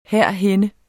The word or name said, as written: herhenne